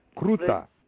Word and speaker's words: groete: G-R-O-E-T-E. Crouta.